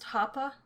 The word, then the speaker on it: Hapa: is it pronounced Hapa? – Yes, yes.